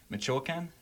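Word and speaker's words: Michoacán: Michoacan.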